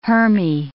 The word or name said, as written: hermae